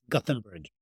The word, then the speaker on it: Gothenburg: In Swedish, it's Göteborg. In English, it's Gothenburg.